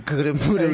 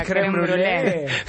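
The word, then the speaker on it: crème brûlée: El creme brûlée. – La creme brûlée. – Pero lo tiene que pronunciar bien.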